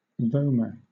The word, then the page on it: vomer: British English